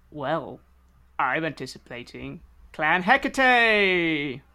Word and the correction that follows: Hecata: I probably said that wrong. Hecate? Hecatat? – Yep. It's the Hecata.